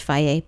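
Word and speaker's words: Fage: It's pronounced Faye, by the way.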